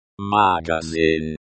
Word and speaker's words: магазин: Magazin. This word is pronounced like magazin. The in at the end sounds like the word in English.